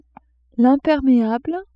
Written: impermeable

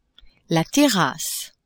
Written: terrasse

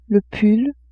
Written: pull

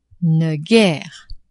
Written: ne-guere

